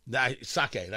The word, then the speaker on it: sake: Sake, that's the way you're supposed to pronounce it.